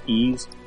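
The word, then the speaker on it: Ys: It's spelled Y-S, but it's pronounced Ease.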